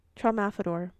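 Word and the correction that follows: Tralfamadore: Oh, Trelfamador. Trelfamador. – Okay. – That's what it is.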